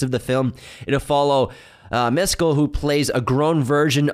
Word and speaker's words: Mescal: Yeah, don't say it like Mescal. – Paul Mescal.